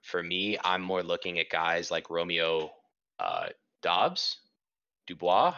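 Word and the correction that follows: Doubs: I don't know how to say his name. – Dubs, yeah. – Romeo Dubs.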